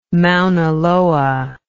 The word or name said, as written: mauna loa